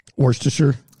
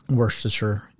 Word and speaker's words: Worcestershire: Worcestershire, whatever, how do you say that? I can never say that. – Worcestershire? – Worcestershire, yeah, sauce.